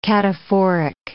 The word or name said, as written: cataphoric